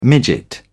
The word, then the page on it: midget: British English